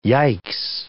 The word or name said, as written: yikes